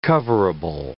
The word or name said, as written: coverable